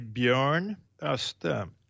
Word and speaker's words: Björn Öste: So I would say Bjorn Öste.